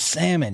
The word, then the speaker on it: salmon: And I would say, it's Salmon. She goes, there's an L in there. And I said, I know, but the L is silent.